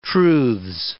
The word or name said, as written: truths